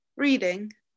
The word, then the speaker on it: Reading: How would you pronounce a town spelled R-E-A-D-I-N-G? – Reading. – Reading. – That's just not English.